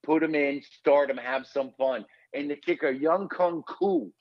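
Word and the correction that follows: Younghoe Koo: Oh, did I say that right? – Young Ho Koo. – There you go.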